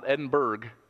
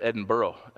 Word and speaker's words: Edinburgh: And it's not Edinburgh. It's kind of the way it's spelled or how we Americans would say it. But it's Edinburgh.